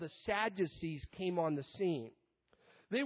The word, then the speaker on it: Sadducees: It also mentions the Sadducees. Now, I like this name because if you pronounce it slowly, it defines itself, sad you see, because that pretty much sums this group up.